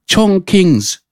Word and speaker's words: Chongqing: Is it Chong? How you say it? Chongqing's. Yeah, Chongqing.